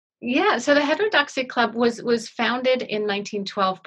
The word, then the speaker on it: Heterodoxy: And there is a women's club at the heart of this storyline called the, I might pronounce this incorrectly, Heterodoxy Club.